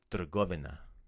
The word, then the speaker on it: trgovina: But the main way to say store is trgovina. – Trgovina. – So let's let's take out that the tra. It's tr trgovina. – Trgovina. – Right. So that trg is basically one really rapid fire syllable.